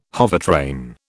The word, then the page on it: hovertrain: British English